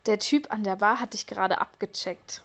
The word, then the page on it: abchecken: German